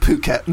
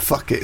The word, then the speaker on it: Phuket: Yeah, but if you say to someone three times, it's pronounced Phuket, not fuck it.